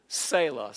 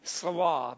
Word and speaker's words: Selah: Some pronounce it Selah. Some say Selah.